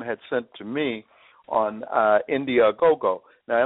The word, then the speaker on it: Indiegogo: You're really close. It's Indiegogo, and what it is, it's a crowdsourcing service. So basically, it's a crowdsourcing campaign called Indiegogo.